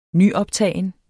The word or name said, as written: nyoptagen